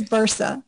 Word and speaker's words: Bursa: It's pronounced Karin, and it's spelled K-A-R-I-N. That's the first name. Last name is easy. It's Bursa, B-U-R-S-A.